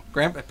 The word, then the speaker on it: Grant: Grant, perfect.